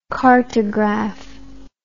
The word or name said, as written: cartograph